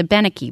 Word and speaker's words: Abenaki: Well, we once did a show with a native speaker who said the correct pronunciation is Abenaki. But if you are Abenaki, we're happy to hear how you say it, too.